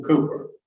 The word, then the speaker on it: Cowper: His name was William Cooper. And you see that word up as C-O-W-P-E-R. But you pronounce it K-O-O-P-E-R.